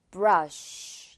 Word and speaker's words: brush: 약간 이렇게 쓰 발음하는 친구들 선생님이 많이 본것 같아요. brush. 약간 쉬에 가깝게 내주면 돼요.